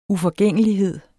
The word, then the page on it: uforgængelighed: ufʌˈgεŋˀəliˌheðˀ